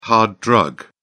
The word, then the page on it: hard drug: British English